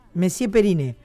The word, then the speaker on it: Monsieur Periné: Y aparte de Carla, junto con Carla viene una banda que se llama, ¿cómo se dice? Monsieur Perin. Monsieur. Monsieur Perin. O Periné. Periné. Monsieur Periné.